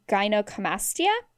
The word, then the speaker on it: gynecomastia: and finally there's this procedure called gynecomastia.